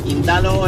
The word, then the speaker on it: Quintalo: Quintalo es una palabra llana y se pronuncia así, como la estoy diciendo.